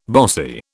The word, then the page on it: bossy: British English